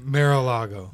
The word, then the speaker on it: Mar-a-Lago: Trump, primary a businessman and a real estate investor at the time, Mar-a-Lago. Is that how you say it? – Mar-a-Lago.